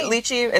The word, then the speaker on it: lychee: Try their lychee or leche. I don't know how you say it. – Lychee. – Lychee. It's lychee. I always feel like I say it wrong, but it's lychee iced tea.